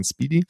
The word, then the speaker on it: SPDY: also SPDY, ausgesprochen Speedy, ähm, was ähm, äh, ausprobieren wollte, wie können wir denn diese Probleme in einem neuen Protokoll lösen.